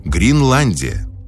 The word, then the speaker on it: Гринландия: Александр Грин и его «Гринландия». Произносится через букву «И», не перепутайте.